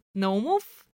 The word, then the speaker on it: Naumov: Okay, so the way I would say Maxim Naumov is Maxim Naumov, which is Bulgarian, not Russian, but, like, Naumov instead of Naumov. Very rarely would you have the first syllable be stressed.